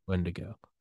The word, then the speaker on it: Wendigo: It's Wrath of the, is it Wendigo or Wendigo? – Wendigo. – Wrath of the Wendigo.